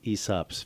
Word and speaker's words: Aesop: Well, let's move on to the Aesop's Fables. And even though there's a big capital A on the beginning of it, it's actually, it's spelled A-E-S-O-P. – Correct. – But it's pronounced Aesop.